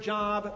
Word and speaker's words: Job: And for those who are newer to the faith, Job is an Old Testament biblical character whose name looks like our English word job, but is pronounced Job.